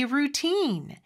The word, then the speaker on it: routine: routine. 이음절의 강세가 있고 모음 소리는 길게 빼 주셔야 합니다.